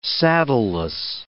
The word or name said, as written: saddleless